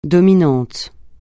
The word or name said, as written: dominant